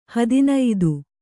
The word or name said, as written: ಹದಿನೈದು